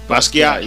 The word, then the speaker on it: Basquiat: Basquiat.